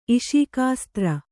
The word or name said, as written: ಇಷೀಕಾಸ್ತ್ರ